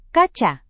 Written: kaca

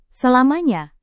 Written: selamanya

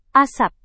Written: asap

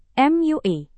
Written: MUI

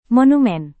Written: monumen

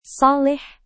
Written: saleh

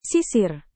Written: sisir

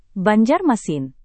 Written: banjarmasin

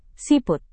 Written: siput

